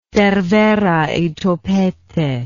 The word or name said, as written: cervera y topete